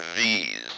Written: these

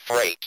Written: freight

